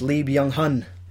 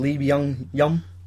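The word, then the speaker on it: Lee Byung-hun: Obviously the actor is Lee Byung Hun, but I think pronounce him as Lee Byung Yum.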